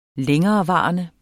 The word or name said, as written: længerevarende